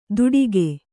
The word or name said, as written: ತುಡಿಗೆ